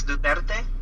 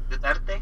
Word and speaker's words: Duterte: It's pronounced as Duterte. – Okay. – Duterte.